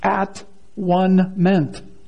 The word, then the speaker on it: atonement: You might say that the word, the way Tyndale came to it, was that it can be broken down to basically be pronounced at-one-ment, atonement, at-one-ment, a bringing to a oneness between God and man.